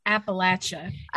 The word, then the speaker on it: Appalachia: I don't know how you pronounce Appalachia. it. Appalachia. Appalachia. It's Appalachia. like throwing an you.